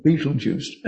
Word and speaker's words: Betelgeuse: It's spelled Betelgeuse. Don't know why it's pronounced like that, but it's called Betelgeuse.